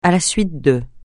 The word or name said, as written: à la suite de